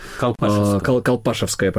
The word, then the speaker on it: Колпашевское: Колпашевское, правильное ударение.